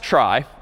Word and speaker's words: trie: So try, short for retrieval somehow, T-R-I-E, but pronounced try.